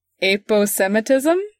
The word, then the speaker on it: aposematism: Aposematism? – Yeah, you said it right.